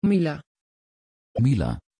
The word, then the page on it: Myla: Dutch